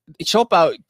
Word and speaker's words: Siopao: Siopao clearly sounds Chinese. It's spelled S-I-O, Sho, and then Pao, P-A-O.